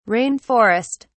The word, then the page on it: rain forest: British English